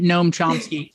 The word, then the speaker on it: Noam Chomsky: So with nativist theory, you should be thinking Noam Chomsky. If I mispronounce his name, please let me know. Pronunciation and I, we don't get along. – No, that's absolutely it. Noam Chomsky.